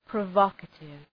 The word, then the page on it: provocative: prə’vɒkətıv